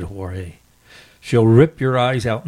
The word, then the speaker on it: Jorge: I think that should be pronounced Jorge.